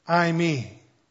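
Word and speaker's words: I, me (εἰμί): You know, what's really funny about this is that the Greek word is pronounced I, me. Now, it doesn't mean I, me in English. It just happens to sound like I, me, and it means I am.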